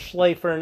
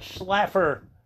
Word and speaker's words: Schlaefer: Like when I hear Tyler Schlaefer's name is pronounced Schlaefer, not Schlaffer.